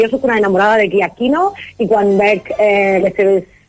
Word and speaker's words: Giacchino: Per si ho voleu buscar, s'escriu Michael en anglès, Michael, i Giacchino és amb G, Giacchino. Giacchino, G-I-A-C-C-H-I-N-O.